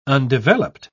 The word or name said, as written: undeveloped